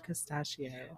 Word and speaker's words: Castaccio: It's called Castaccio. – Oh my, Castaccio. – It's like Anastasia, but for the wine.